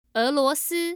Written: eluosi

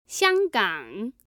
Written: xianggang

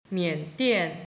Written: mian3dian4

